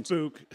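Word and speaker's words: Bouc: It's Book. His name's Book, not Box.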